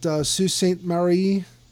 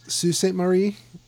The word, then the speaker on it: Sault Ste. Marie: But St. Mary, Ontario is, I guess you would pronounce the full name of it, Sault Ste. Marie, if you were going to go French on it and pronounce it wrong. That's Sault Ste. Marie.